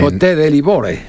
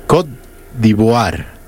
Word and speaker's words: Côte d'Ivoire: A ver, Côte de Livore. – Côte d'Ivoire. En español. – Se pronuncia. – Côte d'Ivoire.